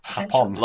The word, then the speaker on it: Los Ingobernables de Japón: Japón. – Los Ingobernables de Japón is how you say it.